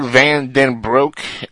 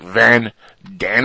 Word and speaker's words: Van Den Broek: Yeah, I you know, you got to say it as it's actually pronounced. Not like, you know, an American would say, which would be like Van Danbroek.